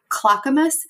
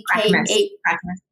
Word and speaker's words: Clackamas: I live in Arizona now, but I grew up in Oregon, just outside of, I'm going to say it wrong, Clackamas, Clackamas.